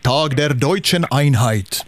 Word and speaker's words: Tag der Deutschen Einheit: Ja, om vi säger det riktigt officiellt på tyska så är det Tag der Deutschen Einheit. – Ja, det är så. Så ja, är det. Fint uttal där.